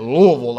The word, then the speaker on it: Louisville: By the way, I had two roommates in college that were from Louisville, and you say it Louisville, like kind of got a mouthful of food, and it's two syllables, okay, Louisville.